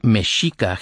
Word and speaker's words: Mexica: The Aztecs, spelled Mexica, pronounced Mexica, hence Chicano, walked south, out of the deserts, on their way to what would become Mexico City.